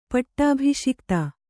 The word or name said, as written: ಪಟ್ಟಾಭಿಷಿಕ್ತ